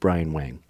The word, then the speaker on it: Bryan Wang: Bryan, and I'm going to say Bryan Wang. – That works. – How about that? But in American, or most Americans would pronounce it Bryan Wang. – Correct.